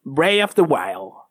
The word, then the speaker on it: Breath of the Wild: No por eso es malo, sino que puede ser mucho mejor que el Bray of the Wild. Oh, Bray of the Wild. Bray of the Wild. ¿Ah? se dice así? Bray of the Wild. Perfecto, que el Zelda.